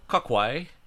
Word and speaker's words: cocuy: They have switched to Kakwe, Kakui, Kakua, C-O-C-U-Y, Kokui, Kokui. – You could have looked up how to say it. – Oh, I could, but we know me. I looked up the article, looking up the pronunciation's a bridge too far. If they don't put the pronunciation in the article, you know, man, next time be like, I don't know how to say this word. Hey, Derek, how to say this word, and I'll look it up. – Nope. All right. So it's called Kakwe.